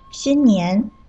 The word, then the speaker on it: 新年: 新年，新是一声，年是二声，你刚才说成了信念，信念就是另外一个词了。